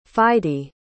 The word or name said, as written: fide